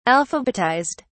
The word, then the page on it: alphabetized: British English